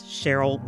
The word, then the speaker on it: Cheryle: You can find her on Twitter at Terri Hord Owens, and Hord is spelled H-O-R-D, at Terri Hord Owens. You can find me, Cheryle Jackson, on socials at Cheryle Jackson. That's Cheryle with an E.